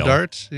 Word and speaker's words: Rodarte: How do you say her middle name? Rodarte Quayle. – Rodarte, yeah.